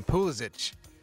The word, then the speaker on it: Pulisic: Pulisic is what they tell me is how you pronounce his name. Pulisic. I guess the U is like an O sound.